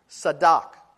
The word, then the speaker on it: tzedak: The Hebrew word for righteousness is a word that's pronounced tzedak. Can you say that? I can't hear you. Tzedak. Now you know some Hebrew. Say tzedak. Yeah, tzedak. Tzedak is a Hebrew word.